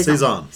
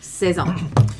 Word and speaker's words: Saison: I think you're saying Saison, like Saigon, but it's not. – I always say it wrong. That's why I wanted to. Okay, Saison. – Saison.